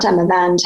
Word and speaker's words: Damavand: How do you pronounce it? Damavan. – Damavad.